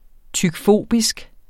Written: tykfobisk

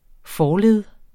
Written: forled

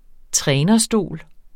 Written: trænerstol

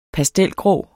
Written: pastelgrå

